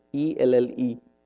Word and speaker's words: Elle: ELLE. That's it? Must be French. Ha, huh. No wonder. You can just write one L and done with. ELLE. And you still say it as L.